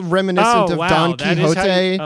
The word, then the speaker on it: Quixote: Well, here's the thing is that I think this is a great discussion to have because it is pronounced Don Quixote, but when weird Anglophone scholars use the word quixotic, they say quixotic, which to me might just be disrespectful of Spanish.